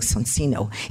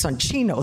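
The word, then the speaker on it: Soncino: I used to pronounce this Sancino. I gave a lecture to a group of Italian Jews. One of them came over after. He said, "Very nice lecture, but the entire time you keep saying Sancino. It's Sancino, Sancino, Sancino." So he traumatized me, and now I always pronounce it correctly.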